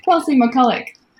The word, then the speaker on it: Kelsea McCulloch: Kelsea McCulloch.